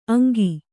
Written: ಅಂಕಿ

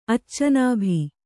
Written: ಅಚ್ಚನಾಭಿ

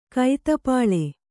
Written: ಕೈತಪ್ಪಳೆ

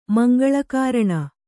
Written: ಮಂಗಳಕಾರಣ

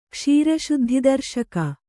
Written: ಕ್ಷೀರಶುದ್ಧಿದರ್ಶಕ

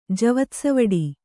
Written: ಜವತ್ಸವಡಿ

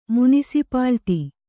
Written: ಮುನಿಸಿಪಾಲಿಟಿ

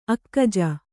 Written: ಅಕ್ಕಜ

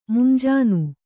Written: ಮುಂಜಾನೆ

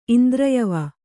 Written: ಇಂದ್ರಯವ

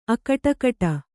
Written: ಅಕಟಕಟಾ

